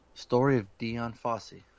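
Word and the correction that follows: Dian: Yeah. Diane. It's pronounced Diane.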